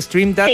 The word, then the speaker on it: Stream Data: Se pronuncia Stream Data, sí.